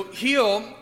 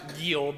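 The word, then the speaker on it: Geel: So Heal, we would pronounce it Giel, but it's actually pronounced like healing. Like that's how we say it.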